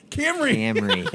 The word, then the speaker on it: Camry: What is it called? I'm saying it wrong. Cameron. – Yeah, you're definitely saying it wrong. – Camry. – There you go.